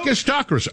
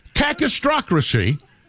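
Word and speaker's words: kakistocracy: Cacistocracy. It's a cacistocracy. No, I pronounced it in practice earlier, in rehearsal. Cacistocracy.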